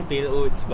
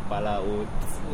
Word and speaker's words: Pala Otak: We purposely call it like the Pale Oats, but if you like try to pronounce it in Malay, it's called Pala Oats.